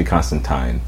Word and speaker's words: Constantine: Actually, speaking of things that are hard to pronounce, it's actually Constantine, which I will never ever yeah, do let's not say that.